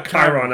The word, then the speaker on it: Chiron: C-H-I-R-O-N. Oh. Chiron? Chiron? Chiron? – Chiron. – We got Chiron out of looking at Google pronunciation. – Google says Chiron. – All right, we're going to go with Chiron.